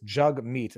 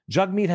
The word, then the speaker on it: Jagmeet: It's Jagmeet, and that's how he says it. Like jug, like a jug, and meet. Oh, jug, like hug, and meet, like nice to meet you. Jagmeet has a taste for dandy luxuries that don't comport with the monkish minimalism of his party.